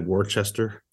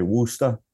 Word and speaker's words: Worcester: How do you spell If you're not from the region, you'll pronounce it that way. If you're from Boston, you'll just say Worcester. – Worcester? Okay. – Yeah, no R's, please.